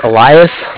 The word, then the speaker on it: Elias: My name is Elias, E-L-I-A-S, and I'm calling from Bank of Cardiff. Cardiff is spelled C-A-R-D-I-F-F, as in Frank.